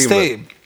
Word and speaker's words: Stage: Der spricht sich Stay. Laut Commentary Live System. Beruhige dich. – Ich sage Stage.